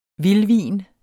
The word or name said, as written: vildvin